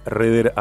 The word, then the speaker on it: Rehder: Iba a decir que tu cuenta de Instagram y tu canal de YouTube es Laura Reder Abogada.